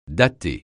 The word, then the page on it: dater: francais